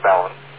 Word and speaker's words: balun: 1:1, and you're describing a 1:1 ballon. And it's pronounced ballon, B-A-L-U-N is the usual spelling, but it's got other spellings, and it's a contraction of balanced to unbalanced.